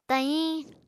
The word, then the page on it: tahiñ: Apatani